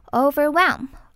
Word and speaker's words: overwhelm: Instead of letting it overwhelm you, organize that project into small, bite-sized, manageable segments.发音的部分我们首先来看到 instead 它的 ea 发的是短音 instead 再来看到 overwhelm、它的 h 是不发音的，所以只需要发 w 的音就好咯。 overwhelm 再来看到 bite size，